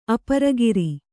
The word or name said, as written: ಅಪರಗಿರಿ